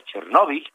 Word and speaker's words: Chernobyl: en realidad se debe pronunciar Chernobyl,